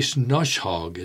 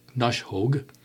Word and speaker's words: Knoshaug: Mary Snoshaug is spelled K-N-O-S-H-A-U-G, Snoshaug, Clarion.